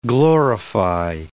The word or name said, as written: glorify